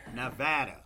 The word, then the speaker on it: Nevada: How do you pronounce Nevada? – No. Do you eat apples? Right? Apples.